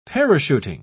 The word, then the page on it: parachuting: British English